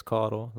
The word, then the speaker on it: Caudill: You can find me on Twitter at Chance Caudill. The last name is spelled C-A-U-D-I-L-L.